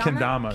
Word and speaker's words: kendama: How do you say it? Kendama? – Kendama. Kendama. – K-E-N-D-A-M-A.